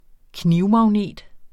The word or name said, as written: knivmagnet